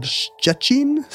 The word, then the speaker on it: Szczecin: Szczecin? – Szczecin. – I don't know. – It's got... It doesn't have any T's in it. It's spelled S-Z-C-Z-E-C-I-N. – We don't have a lot of words in English that start with the sh sound.